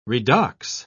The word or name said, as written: redox